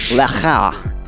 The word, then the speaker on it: Lachow: Am I saying this right? Sam Lachow? Lachow. – Yeah, I feel like it when the CH is in the middle of the name, it's got a Lachow. He's French.